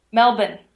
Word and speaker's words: Melbourne: Say it, you can't pronounce the R in there. – Yeah. What is it then? – Melbourne.